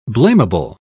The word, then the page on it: blamable: British English